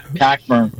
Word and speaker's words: Cockburn: Is it Colburn or Cockburn? – Cockburn. – Yeah. – I think.